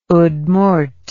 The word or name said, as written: Udmurt